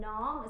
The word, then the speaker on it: Nam: And it's pronounced Sat, like S-U-T, even though it's spelled S-A-T. And then Nam is kind of like, you know, good food when you say like Nam, Nam, Nam. It's like that kind of Nam.